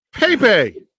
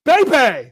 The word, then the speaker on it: Bhebhe: I mean, how do you pronounce B-H-E-B-H-E? I have no idea. – Pepe. – Pepe.